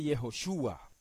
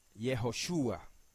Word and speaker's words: Yehoshua: pero cuando se transcribe se transcribe generalmente como Yehoshua y se lee Yehoshua, Yehoshua.